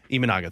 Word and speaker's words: Imanaga: Imanaga, thank you.